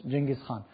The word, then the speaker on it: Genghis Khan: It's Genghis Khan. That's how we pronounce it in English, Genghis Khan.